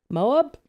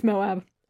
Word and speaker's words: Moab: Moab? Definitely saying that wrong. – It's Moab.